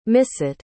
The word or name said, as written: misset